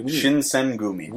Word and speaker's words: Shinsengumi: Stories of the Shinsegumi. I'm saying that wrong, too. – Shinsengumi.